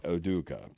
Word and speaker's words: Ime Udoka: Ime Udoka. – Udoka.